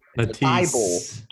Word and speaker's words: Matisse: Hold up, hey, can you pronounce that first name again? – Mattis, I can never say it right. – Matisse.